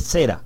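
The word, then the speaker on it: será (זֶרַע): ¿Cuál sería la pronunciación correcta, mis compañeros? ¿Es será, porque aparece en Strong con acento en la E, o es será, como siempre la escucho decir? Es será. Ok, acento L, muchas gracias.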